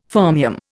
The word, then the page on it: fermium: Indian